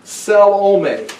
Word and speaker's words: Salome: Let me make sure I say it right. I had to check the pronunciation. Salome.